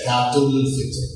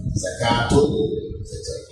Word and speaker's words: Zakatul Fitr: If you say something after it, then you pronounce the T. You say Zakatul Fitr. Zakatul Fitr. So the T is pronounced there.